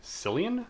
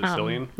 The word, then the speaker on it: Cillian: Cillian Murphy? – No, that's right. – Is it Cillian? – Yeah.